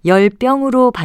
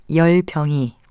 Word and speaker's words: 열병: 한 가지 주의할 건 이 단어의 발음입니다. 열병으로 발음하는 경우가 많은데 예사소리인 열병이 올바른 발음입니다.